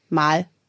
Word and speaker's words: meget: Also die haben so Wörter, die schreibst du M-E-G-E-T und das wird ausgesprochen mal.